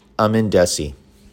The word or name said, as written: Aman Dhesi